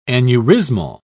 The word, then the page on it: aneurysmal: British English